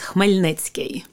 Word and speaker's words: Khmelnytskyi: It's Khmelnytskyi. – Yeah, and you spelled this for me earlier, but Khmelnytskyi. – That's correct.